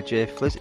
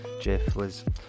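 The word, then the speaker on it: J Fliz: I was actually sent an EP by Jay Fliz. Is that how you pronounce the name? Is it J and then F-L-I-Z? Jay Fliz. Or F-L-I-Z, as they say in the US. Jay Fliz.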